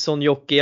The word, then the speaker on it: Soinjoki: Ja, nej, men då, då, sitter vi här också med Elias Sonjocki. Jag ber om ursäkt för uttalet av efternamnet, men var det någorlunda rätt kanske eller? Det var svinbra ja men du ser, ibland så plötsligt så händer det.